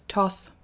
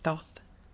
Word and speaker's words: Thoth: Thoth, some people pronounce as Toth. I say Thoth. T-H-O-T-H.